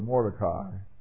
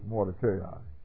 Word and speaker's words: Mordecai: Now, I know that most of you are used to pronouncing that word Mordecai, but actually it's Mordecai.